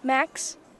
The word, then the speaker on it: Max: Max. – Max, can you spell that? – M-A-X.